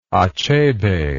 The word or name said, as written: achebe